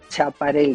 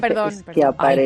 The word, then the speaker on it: Schiaparelli: No se dice Schiaparelli. – Perdón. – Schiaparelli. – Ay, pobre Laurita, que no sabe italiano.